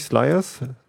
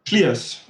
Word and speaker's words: Schliers: Wie, wie spricht man den aus? Nicht Sliers? – Schliers.